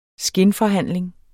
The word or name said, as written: skinforhandling